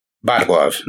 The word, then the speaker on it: Borgov: Bargov, né? Borgov. – Reparou que o árbitro russo, ao dar início à rodada, pronuncia Bargov? Bargov.